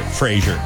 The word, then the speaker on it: Fraser: I always think of Treasure, Pleasure. Yeah. But it actually, that's how you pronounce it, Fraser.